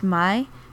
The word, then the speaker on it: Mai: And then May is in fact pronounced Mai because her full name is Mai Valentine, and it's a pun.